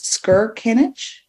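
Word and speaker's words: Skirkanich: Peter, I'm going to butcher this, Skirkinich? – Yes, that's right.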